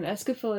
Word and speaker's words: Escafil: Escafil is how I pronounced it. – I always feel like a scaffold, like scaffolding. – That's stupid. – The Escafil device. – Because scaffolding is an O, but Escafil is an I.